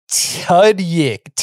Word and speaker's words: Tudyk: T-U-D-Y-K.